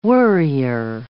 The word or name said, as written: worrier